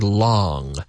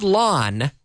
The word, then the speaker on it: long: We have the word long. Not lawn. This was césped. Long. Long. Long. I'm closing the back of my throat with the back of my tongue.